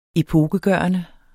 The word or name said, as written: epokegørende